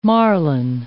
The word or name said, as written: marline